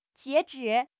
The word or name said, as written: 截止